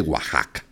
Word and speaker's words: Oaxaca: hay gente que lee exactamente como, como se escribe y dicen que es de Oaxaca. No, pues es de Oaxaca.